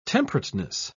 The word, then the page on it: temperateness: British English